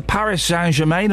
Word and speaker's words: Paris Saint-Germain: And in sport, Chelsea lost 3-1 away to Paris Saint-Germain.